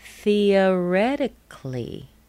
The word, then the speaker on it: theoretically: Theoretically. Remember that C-A-L-L-Y will be pronounced cli.